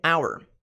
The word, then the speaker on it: our: A pronúncia completa dela, muita gente aprende como our, our, our.